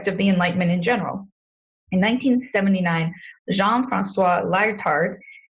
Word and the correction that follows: Lyotard: I butchered that, it's French, Lyotard, published a book called The Postmodern Condition.